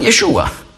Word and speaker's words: Yeshua: Natürlich, wenn ich in Israel predige, dann sage ich Yeshua. Aber weil man auf Hebräisch sagt man Yeshua. Aber in der Schweiz sagt man Jesus. Und das ist also die griechische Aussprache.